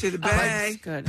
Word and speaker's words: Sidibe: Actress, tell me if I'm pronouncing her name correct, Gabori Sidibe. – Sidibe. – Thank you. Precious. I never saw that movie. – Sidibe.